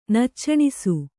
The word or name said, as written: ನಚ್ಚಣಿಸು